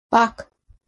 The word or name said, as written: Bach